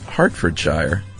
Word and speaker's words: Hertfordshire: Hertfordshire is pronounced Hartfordshire. – Oh, we were way off. – I admit it, it isn't spelled H-A-R-T, but then that is just the English language for you.